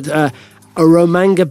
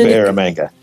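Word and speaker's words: Eromanga: Now, you've announced two announcements there on acquisitions in Australia in the Cooper, I don't know if you said this, I'm saying this right, Aromanga Basins. Now, is this Cooper Aromanga? – That's it. You said it perfectly.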